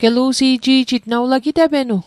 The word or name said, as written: gelu'si'ji'jit